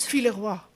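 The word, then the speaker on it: Villevoye: Roy Villevoy, zeg ik dat goed? – Villeroy. – Villevoix.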